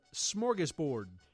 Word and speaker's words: smorgasbord: S-M-O. S-M-O-R-G-A-S-B-O-R-D. – Smorgasbord. – Very good. – It was good, because I always mispronounce it as a youth and called it a smorgasbord. – Yeah, so but there's no shm. – There's no shm. – Yeah. – There's only a sm.